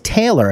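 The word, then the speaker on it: Talor: It's T-A-L-O-R, but it's pronounced Taylor.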